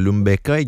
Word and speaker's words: Lumbeca: Hey, tu viens de parler de vos deux grandes nouveautés, le Vasculase, le Lumbeca également, c'est comme ça qu'on le prononce? – Oui, tu donnes une petite twist.